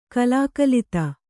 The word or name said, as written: ಕಲಾಕಲಿತ